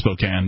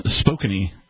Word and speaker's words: Spokane: In Washington it's pronounced Spokane, but... – Spokane? – Spokane.